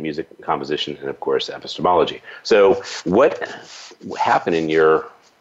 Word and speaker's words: epistemology: I guess it's, I have never even pronounced it, epistemology, is that right? – Oh, yes, epistemology, yes.